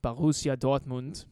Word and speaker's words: Borussia Dortmund: Sorry, let me pronounce it right. Borussia Dortmund.